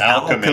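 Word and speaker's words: Owlchemy: Alchemy. It's Alchemy. Alchemy. Yes. Yeah. Alchemy. But it's spelled Owl. O-W-L.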